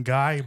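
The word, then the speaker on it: Gai: It's called the Golden Guy, but it's spelled G-A-I. I don't know what guy. It's not like the guy, but it's this little neighborhood.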